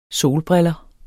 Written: solbriller

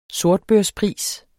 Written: sortbørspris

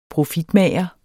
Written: profitmager

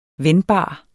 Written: vendbar

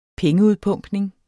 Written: pengeudpumpning

